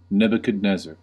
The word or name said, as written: Nebuchadnezzar